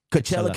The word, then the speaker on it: Coachella: Coachella Coachinas were there, bro. – Coachella. – How do you say it? – It's Coachella.